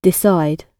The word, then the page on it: decide: British English